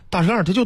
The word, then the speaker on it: 大栅栏: 写各有不同，其中有 3个站牌是以大栅栏的拼音标注的，另外 7个站牌呢写的是大山栏、还有一个写了大沙栏。 – 嗨，好，那么其实呢这个汉字啊，木字旁加一个错这字儿组成词语，栅栏一词的时候确实应该读栅，但是在北京大栅栏它就读成大栅栏，对吧？